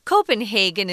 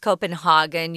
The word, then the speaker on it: Copenhagen: Um, I wanted to mention uh, the sometimes this city Copenhagen is pronounced Copenhagen. You can do either one, um, but it's in Denmark.